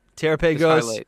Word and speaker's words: Terrapagos: Every single time you're saying it wrong. I know you're trying to say it right. It is not. – Terra. All right. Terrapagos.